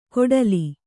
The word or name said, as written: ಕೊಡಲಿ